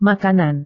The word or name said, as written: makanan